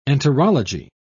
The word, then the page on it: enterology: British English